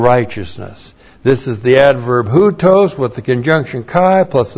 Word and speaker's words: houtos: The adverb houtos. H-O-U-T long O-S, houtos.